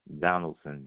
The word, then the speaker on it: Donaldson: Michael, M-I-C-H-A-E-L. Donaldson, D-O-N-A-L-D-S-O-N. – You said Michael, M-I-C-H-A-L-E. – A-E-L. – A-E-L, I'm sorry.